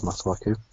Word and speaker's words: Masuaku: Masuaku? – Yes, it is Masuaku.